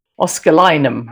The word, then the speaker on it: Oscillococcinum: And I'm always pronouncing it wrong, and I'm just holding it up for those who are on the video to see. Oscillinum, I should call it. And I will just spell it O-S-C-I-L-L-O-C-O-C-C-I-N-U-M.